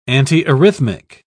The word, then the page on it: antiarrhythmic: British English